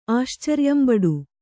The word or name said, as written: ಆಶ್ಚರ್ಯಂಬಡು